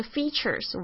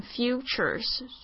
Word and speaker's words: features: 好，那这个 features 我们要特别注意一下它发音，以什么为特色，这个在这边它是当这个动词。这个 features 常常有学生会念成 futures 是错的。好，这个要注意一下 ,ea 是发 e 的长音 ,features。